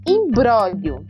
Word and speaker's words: imbróglio: Então, a palavra é imbróglio. – Imbróglio. – Isso, só que ela tem um Gzinho mudo aí. Então, é I-M-B-R-O com acento agudo, né? G-L-I-O. Então é imbróglio, mas a gente pronuncia imbróglio.